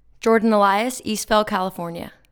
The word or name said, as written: Jordan Elias